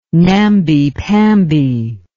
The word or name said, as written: namby pamby